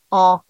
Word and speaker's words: an: There's you know, EN, which means, you know, in, like en France, but then AN is pronounced en, and it means year.